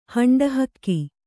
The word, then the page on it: ಹಂಡಹಕ್ಕಿ: Kannada; haṇḍa hakki